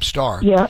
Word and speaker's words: Starr: Of course, I was calling him Bob Starr at the time, but it's Bob Starr because he has two R's you have to pronounce at the end of his name.